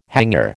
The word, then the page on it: hangar: American English